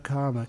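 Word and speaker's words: kāma: That word kama, K long A M A,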